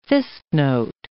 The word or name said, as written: fistnote